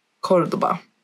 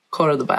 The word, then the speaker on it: Córdoba: Córdoba. Och ja, Córdoba är det korrekta uttalet för att först klargöra det. Det har funnits många olika varianter av uttalet genom åren, men Córdoba är det korrekta.